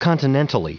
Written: continentally

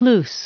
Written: loose

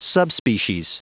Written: subspecies

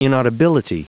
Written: inaudibility

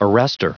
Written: arrestor

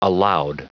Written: aloud

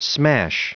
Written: smash

